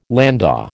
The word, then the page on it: landau: American English